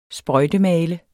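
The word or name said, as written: sprøjtemale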